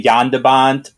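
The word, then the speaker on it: Jan de Bont: I mean, you can talk about how LPJ's pronunciation is very Anglo-centric, so he refuses to say Yandabant or homage. – John DeBont, director of Twister.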